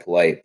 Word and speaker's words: Polite: Also, as an aside, I think the guy getting mocked to the Lions more than anyone is that Polite guy. I don't know if that's how you say it, out of Florida. – Ja'Kai Polite, yeah.